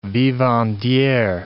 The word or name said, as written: vivandiere